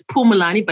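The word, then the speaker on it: Phumulani: Actually, it's pronounced Pu, like, so it's Pumulani, but it's spelled with a P and a PH. – Okay, so the H is silent.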